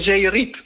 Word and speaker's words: Zeerijp: Met de klemtoon op Rijp. Want als je het in Groningen zegt, dan zeg je Zeerijp. – Zeerijp. – En dan is de klemtoon ook op het Riep in plaats van het Rijp.